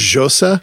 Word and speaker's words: Xhosa: I think Osa. It's Xhosa. – It's a click language.